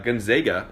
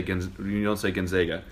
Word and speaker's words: Gonzaga: We've got our top four seeds this year are Villanova, Kansas, UNC, and Gonzaga, which is how it's properly pronounced. I know growing up in Atlanta, it was called Gonzaga. People get really upset when you say Gonzaga.